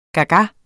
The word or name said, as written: gaga